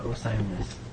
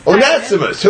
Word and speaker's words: Onesimus: I didn't even know Onesimus. – How did you say that? – I missed the Ness. – Onesimus.